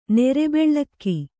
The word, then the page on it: ನೆರೆಬೆಳ್ಳಕ್ಕಿ: Kannada